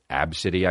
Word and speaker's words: ABCDE: Absidy, I guess, which, you know, come on down to Absidy. Tired of your gut? We've got fucking exercise for days. Her name was Absidy, spelled A-B-C-D-E.